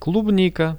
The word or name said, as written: klubnika